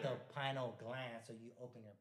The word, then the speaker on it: pineal: Pineal gland is blinking. Right? – Hey, how do you pronounce pineal? – I say pineal gland.